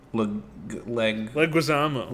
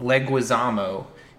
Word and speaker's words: Leguizamo: But John Leguizamo. – Leguizamo.